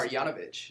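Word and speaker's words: Marjanović: Marjanovic.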